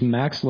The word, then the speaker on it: Max: My name is Max Lorenz. That's Lorenz. – Sorry. Yeah, the T is silent. They just threw that in there for fun. It's Max Lorenz, M-A-X, L-O-R-E-N-T-Z.